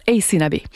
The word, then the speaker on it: Aysanab: Alors, je vous épelle son nom, c'est A-Y-S-A-N-A-B. Ça se prononce Ace Nabi.